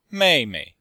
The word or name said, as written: mei4mei5